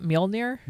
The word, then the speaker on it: Mjolnir: How do you say that? Mjolnir? – Mjolnir.